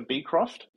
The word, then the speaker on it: Beecroft: We tend to emphasize the B, so Beecroft.